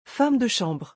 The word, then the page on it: femme de chambre: French